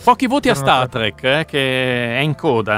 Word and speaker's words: Star Trek: No, non so, ho sempre sentito dire Star Trek. – Star Trek.